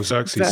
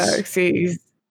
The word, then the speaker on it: Xerxes: Oh, Xerxes. – Xerxes.